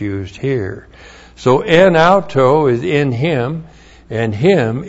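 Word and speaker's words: En auto (ἐν αὐτῷ): And then we have en auto. Preposition en plus the dative of autos, that's the dative form there. E-N-A-U-T long O. En auto.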